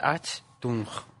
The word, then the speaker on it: Achtung: En realidad es Achtung, pronunciado según se lee, pero bueno.